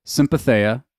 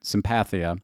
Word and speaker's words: sympatheia: And the idea is sympatheia. Or some people are pronouncing it sympathia, but it's the idea of the interconnectedness of all things.